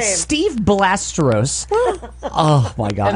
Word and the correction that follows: Seve: And then Mark would always have to be like, I think it's pronounced Seve.